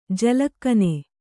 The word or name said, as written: ಜಲಕನೆ